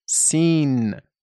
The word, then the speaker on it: سین: و سین این طور تلفظ می شود. سین.